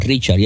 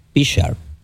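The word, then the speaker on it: Prichard: Déjame pronunciar bien porque algunos dicen Richard y es Pichard con P.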